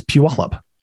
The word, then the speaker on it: Puyallup: It's spelled P-U-Y-A-L-L-U-P, which most people say Puyallup. So it's Puyallup.